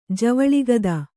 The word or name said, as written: ಜವಳಿಗದ